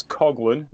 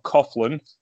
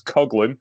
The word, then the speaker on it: Coughlin: That's Coughlin, not Coughlin, like, you know, the English and Irish pronunciation. It's Coughlin. Manabu Nakanishi and Michael Richards in the opening match.